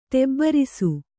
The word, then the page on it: ಟಿಬ್ಬರಿಸು: ṭibbarisu